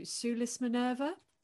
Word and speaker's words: Sulis Minerva: So the subject of your first book was the temple to, and you have to correct me if I say this wrong, to Sulis Minerva. – That is right. Yes, that's the correct pronunciation.